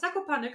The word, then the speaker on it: Zakopane: Habe ich das richtig gesagt? – Zakopane, genau. Ja, okay. Also es wird eigentlich genauso ausgesprochen, wie es geschrieben wird.